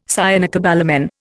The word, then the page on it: cyanocobalamin: Indian